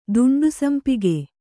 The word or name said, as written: ದುಂಡುಸಂಪಿಗೆ